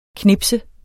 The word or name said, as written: knips